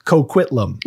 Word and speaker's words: Coquitlam: We can say Coquitlam.